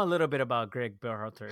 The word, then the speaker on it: Berhalter: The era of the Berhalter. Did I say that right? – Yeah, Greg Berhalter. You got it.